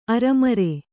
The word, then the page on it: ಅರೆಮರೆ: Kannada